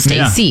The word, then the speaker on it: Stacy: And you'd add a Y or an E sound, Stacy. Yeah. Still two syllables, but my abbreviation makes sense.